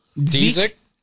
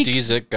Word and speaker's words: Dziedzic: How do you say that name? D-Z-I-E-D-Z-I-C? – Dezik? – The Deek. – Kelly Dezik, I would say.